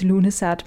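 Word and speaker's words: Lughnasadh: Ja, det udtales Lunesat, men det staves på en meget forvirrende måde, og ja, du kan se, hvordan det staves her i podcastens titel.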